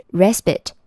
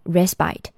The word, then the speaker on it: respite: 核心词语是 respite，也可以读成 respite。